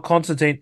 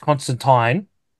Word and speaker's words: Constantine: It's not Constantine, it's Constantine.